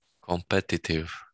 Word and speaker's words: competitive: In the end, competitive, no, compare, in the second syllable. – Exactly. So it's kind of competitive, right? So you have to kind of visualize like the like as if it's music. Competitive, not competitive.